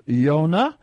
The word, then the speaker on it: Yonah: Well, you know, there's no Y in Hebrew. So the way to pronounce it in Hebrew is Yonah.